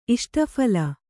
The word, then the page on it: ಇಷ್ಟಫಲ: Kannada